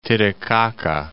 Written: Titicaca, Lake

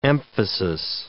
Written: emphasis

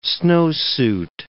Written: snowsuit

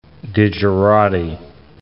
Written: digerati

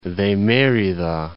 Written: Cordillera de Merida

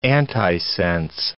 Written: antisense

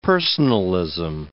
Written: personalism

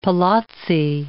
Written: palazzi

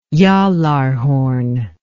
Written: gjallarhorn

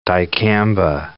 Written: dicamba

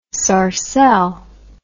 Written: sarcelle

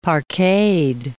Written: parqueted

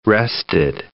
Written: rested

